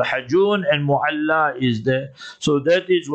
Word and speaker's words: Mu'alla: So she is buried there in Mu'alla, in the graveyard. Not Mala, Mala like a Malkop. You understood? Mu'alla.